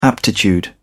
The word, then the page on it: aptitude: British English